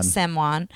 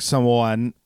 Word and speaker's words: Samoan: Samoan. I think it's more like Samoan. Like you're doing a very quick O to the A.